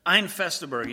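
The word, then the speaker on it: Ein feste Burg: You gotta say it like a German. There's nothing in that that makes you like spit at people, because that's not one of those kind of words, but Ein Fester Berg.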